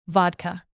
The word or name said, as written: vodka